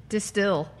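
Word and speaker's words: De Stijl: There's a J in there. – What's that about that art? I think that's how you pronounce that artist's name or that art movement name. – All right. – Distill.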